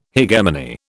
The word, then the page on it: hegemony: British English